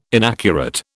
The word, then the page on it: inaccurate: British English